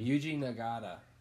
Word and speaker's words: Yuji Nagata: Yuji Nagata.